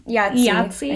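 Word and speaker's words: IATSE: So the International Association of Theatrical and Stage Employees, or how do we want to pronounce this acronym? – Yahtzee. I think it's pronounced Yahtzee.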